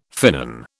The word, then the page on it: finnan: British English